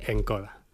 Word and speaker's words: Nkodia: Que se escribe, permíteme, N-K-O-D-I-A. – Efectivamente. – Así se escribe. – Encoda.